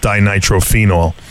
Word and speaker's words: dinitrophenol: First of all, I want to be a little bit of a wise ass, and I want to tell you that I find it interesting the way you pronounce it. I always was under the belief that it's pronounced dinitrophenol.